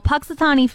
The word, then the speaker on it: Punxsutawney: Well, Punxsutawney Phil.